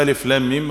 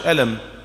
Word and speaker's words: الم: يعني لما بشوف ألف لام ميم ما بقرأهاش ألم زي ما بقرأ في أي موطن في القرآن، خلاص؟ ما بقولش ألر، لا، بنطق كل حرف ايه؟ وحده، بقطعه عما بعده.